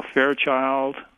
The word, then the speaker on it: Fairchild: You know, Fairchild is, you know, well, emphasis on the first syllable again, two-syllable word.